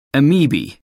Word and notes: amoeba: American English

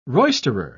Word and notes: roisterer: British English

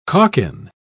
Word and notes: calkin: British English

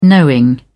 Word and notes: knowing: British English

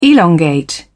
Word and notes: elongate: British English

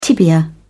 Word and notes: tibia: British English

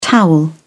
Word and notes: towel: British English

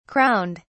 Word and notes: crowned: British English

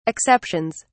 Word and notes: exceptions: British English

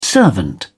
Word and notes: servant: British English

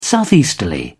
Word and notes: southeasterly: British English

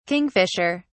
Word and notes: kingfisher(s): British English

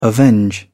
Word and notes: avenge: British English

